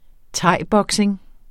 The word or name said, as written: thaiboxing